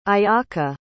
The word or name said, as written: ayaka